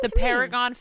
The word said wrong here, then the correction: peregrine: Falcon? – Peregrine Falcon. Peregrine Falcon. Peregrine? – P-E-R-E-G-R-I-N-E.